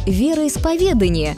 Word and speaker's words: вероисповедание: Например, вы знаете, где поставить ударение в слове «вероисповедание»? Да, именно на «е», а не на «а». Веру исповедуют, и никак иначе. Вариант с ударением на «а» в словарях трудностей русского языка даже специально помечен как ошибочный.